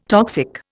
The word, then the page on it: toxic: Indian